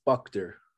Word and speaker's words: Buchter: It's Buchter. – Buchter. Okay, fine. So it's just so everyone knows, it's B-U-C-H-T-E-R.